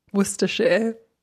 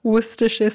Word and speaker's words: Worcestershire: Worcestershire. – Enci una volta. – Worcestershire sauce.